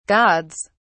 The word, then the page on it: gods: British English